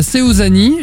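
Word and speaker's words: Suzane: Dans un instant, Christophe Maé et Seuzani. Je l'ai bien prononcé ou pas, Ombline? – Très bien. – Très bien, oui.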